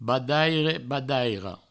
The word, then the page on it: badaire, badaira: badaire, badairo; Provençal